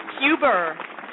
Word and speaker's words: Huber: Huber. Yep, you got it.